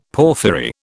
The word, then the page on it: porphyry: British English